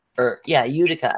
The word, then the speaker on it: Utica: Utica, I think it's called. If that's how you pronounce it.